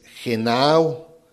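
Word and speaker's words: genao (γεννάω): La primera palabra donde dice que el que no naciere, naciere, viene de una palabra griega que es genao, gena, gena, o. Es con doble N, gena, acento en la A y al último la O, gena, gena, o.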